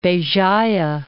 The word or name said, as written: Bejaia